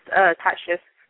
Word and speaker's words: Tasha: This is Tasha. – Sorry, what was your name? – Tasha. – How do you spell that? – T-A-S-H-A.